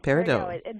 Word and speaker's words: peridot: Is it Peridot? Peridot? – Peridot. – Peridot.